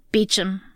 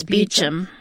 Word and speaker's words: Beecham: Beecham. – Beecham. How come I can't? Because I keep seeing it. – Like Toby Meacham, but it's Beecham.